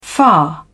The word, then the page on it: far: British English